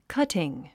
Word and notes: cutting: kʌ’tiŋ; カティン

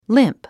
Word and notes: limp: límp; リムプ

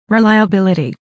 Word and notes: reliability: rilàiəbíləti; リライアビリティ

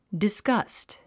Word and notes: disgust: disɡʌ́st; ディスガァスト

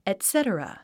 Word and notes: etc.: ètsétərə; エトセトラ

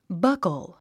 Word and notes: buckle: bʌ’kl; バッコゥ

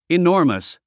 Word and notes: enormous: inɔ’ː r məs; イノーマス

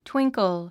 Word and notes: twinkle: twíŋkl; トゥインコゥ

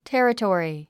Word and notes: territory: térətɔ̀ːri; テェレトリィ

